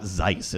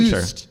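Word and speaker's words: Züst: You're not pronouncing Zeus. It's not Zeiss, is it? Because that's... – No, it's just a U with the umlaut. – Okay. – Zust.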